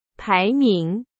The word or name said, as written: 排名